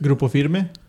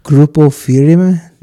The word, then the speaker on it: Grupo Firme: For the viewers at home, how do you pronounce that? – Grupo Firme. – Grupo Firme.